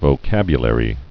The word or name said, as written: vocabulary